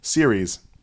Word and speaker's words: Ceres: Series. – Irish? – Nope. – Oh, series. – See, I could have been pronouncing this incorrect. It's spelled C-E-R-E-S.